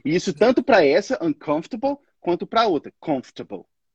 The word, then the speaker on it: uncomfortable: Uncomfortable. – Good. Essa aí é meio tricky mesmo, essa palavra. A gente, na verdade, não pronuncia aquele O e o R. Então, a gente fala uncomfortable.